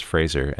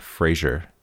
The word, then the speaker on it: Fraser: he recently has gone on and been very outspoken about how his last name is pronounced Fraser and not Frasier, which is crazy because everyone everywhere all at once has been calling him Brendan Fraser forever.